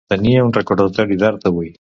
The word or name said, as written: recordatori